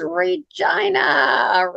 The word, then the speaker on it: Regina: But that's, you know, we help people through that little problem by saying that, you know, we live in the city that rhymes with fun. They're right. Okay. It's Regina.